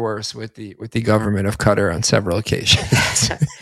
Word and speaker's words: Qatar: So Qatar, is that the actual? I've added Qatar is the proper pronunciation.